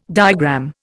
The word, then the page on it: diagram: Indian